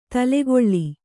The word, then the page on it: ತಲೆಗೊಳ್ಳಿ: talegoḷḷi